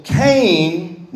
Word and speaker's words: Qayin: So Cain's name in the Hebrew is Cain. It's spelled Q-A-Y-I-N. Cain. And what his name means, it comes from a root, which is Kana. It's Q-A-N-A-H.